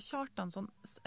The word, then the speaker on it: Kjartansson: Tror dere at dere fra nå av klarer å uttale Kjartanssons etternavn riktig? KJ-lyden finnes ikke I andre språk enn det norske. – Kjartansson. – K og J uttales separat, ja, med vennlig hilsen språk, Petter Møter.